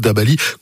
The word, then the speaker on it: Dabali: Dabali, comme ça se prononce, Dabali.